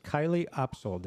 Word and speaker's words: Kiley Opsal: Her name is Kiley Opsal.